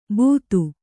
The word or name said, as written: ಬೂತು